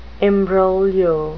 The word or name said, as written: imbroglio